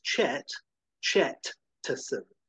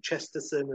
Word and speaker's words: Chet Chetson: That's Chet Chetson. And no one can pronounce his name. It's like a running joke. People say Chesterson and so forth, and he's always like having to correct them.